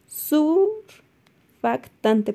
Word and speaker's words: surfactante: Se presenta pues en pacientes que reciben altas concentraciones de oxígeno, lo cual pues, va a producir un mal funcionamiento de superfacte, perdón, no lo puedo pronunciar bien, surfactante pulmonar. Ahora sí.